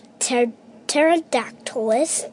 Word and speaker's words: Pterodactylus: Pterodactylus. See, the P is silent.